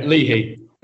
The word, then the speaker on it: Leahy: Tom, am I pronouncing that right? – No, that's fine, mate. We'll roll with it. It's Leahy.